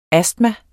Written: astma

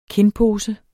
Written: kindpose

